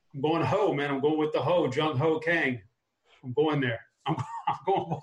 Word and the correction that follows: Kang: It's Jung Ho Gung. – Really? It's spelled K-A-N-G like that.